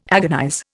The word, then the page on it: agonize: Indian